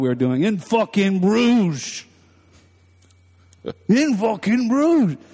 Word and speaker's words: Bruges: Yeah, how do you say that word? – Bruges. – Bruges. Bruges. Okay. I keep thinking burgers.